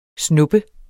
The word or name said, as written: snuppe